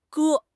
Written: 割

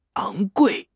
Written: 昂贵

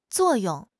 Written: zuo4 yong4

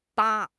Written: 疤

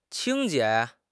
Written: 清洁

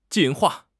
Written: 进化

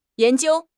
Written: yan2 jiu1

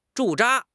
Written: 驻扎

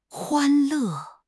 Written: 欢乐

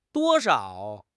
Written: duo1 shao5

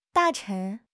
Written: 大臣

